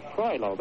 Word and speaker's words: Troilo: Troilo, that's the way he pronounced it. T-R-O-I-L-O. Troilo is the blocker.